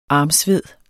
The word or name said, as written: armsved